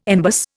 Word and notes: embus: Indian

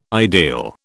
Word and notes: ideal: British English

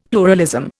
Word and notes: pluralism: Indian

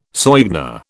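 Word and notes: soigné: British English

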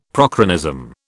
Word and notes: prochronism: British English

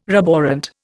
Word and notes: roborant: Indian

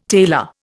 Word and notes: tailor: Indian

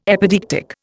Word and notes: epideictic: Indian

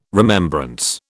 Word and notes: remembrance: British English

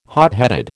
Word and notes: hotheaded: American English

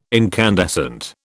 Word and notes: incandescent: British English

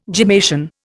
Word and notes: gemmation: Indian

